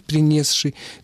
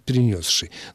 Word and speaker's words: принесший: «принесший». Но, повторяю, строго нормативно «принесший».